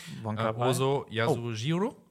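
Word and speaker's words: Ozu Yasujirō (小津安二郎): jetzt Achtung, Ozu Yasujirō, ich habe es richtig ausgesprochen, und der hat so Sachen gemacht wie, Moment, ich gucke es extra nach.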